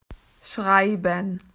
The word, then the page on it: to write: Australian English